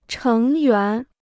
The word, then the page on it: 成员: chéng yuán